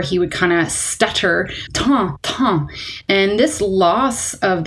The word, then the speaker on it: Tan: And this patient had been nicknamed Tan, and it's actually spelled T-A-N. And so if you're Anglophone, you might look at it and say it's Tan. But remember, this person was French, so it's actually pronounced Tan, like time.